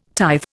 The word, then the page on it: tithe: Indian